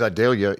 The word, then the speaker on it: Idalia: Because Idalia rhymes with Vidalia.